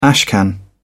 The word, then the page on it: ashcan: British English